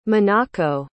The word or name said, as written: minako